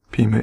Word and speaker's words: Pima: Now, because he was a Pima, is that how you say it? – Pima Indian.